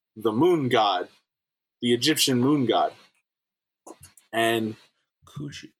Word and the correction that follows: Khonshu: Con, or Khonshu, Sorry, Khonshu. I said that wrong. It's not C. It's K O N C H U.